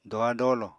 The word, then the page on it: doha-dolo: apatani